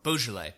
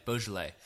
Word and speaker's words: Beaujolais: But you can also get some of the the Rhone wines um, from the lesser known Rhone regions or Beaujolais or Bordelais. No, I'm not. I'm saying it wrong. Beaujolais, Beaujolais. Uh, you can get Beaujolais for for pretty inexpensive, or and and like for example Loire uh, Cabernet Franc.